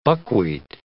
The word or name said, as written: buckwheat